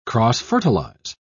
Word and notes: cross-fertilise: British English

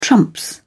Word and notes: trump: British English

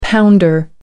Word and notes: pounder: American English

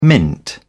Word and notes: mint: British English

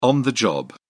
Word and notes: on the job: British English

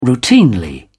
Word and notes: routinely: British English

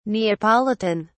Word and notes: neapolitan: British English

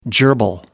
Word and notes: gerbil: American English

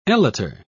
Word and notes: elater: British English

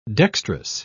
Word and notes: dextrous: British English

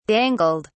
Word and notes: dangled: British English

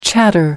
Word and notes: chatter: American English

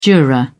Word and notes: juror: British English

